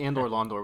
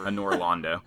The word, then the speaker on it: Anor Londo: I was going to say Andor Londor, but that's the area, right? – No, and that's not even how you say it. – Whatever. – Anor Londo.